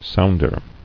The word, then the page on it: Sounder: sound·er